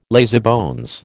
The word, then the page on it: lazybones: American English